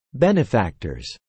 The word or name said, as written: benefactors